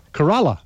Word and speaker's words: Corolla: Corolla.